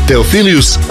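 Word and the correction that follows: Theophilus: Ja sam rekao, nije Teofilius. – Teofilus, malo ga skrati, ti ga produžio.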